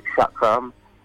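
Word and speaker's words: chakram: Well, obviously she's got various weapons, but I think the main one is the, I think it's pronounced as Shakram, which is that circular kind of ring that she throws and kind of returns back to her like a boomerang.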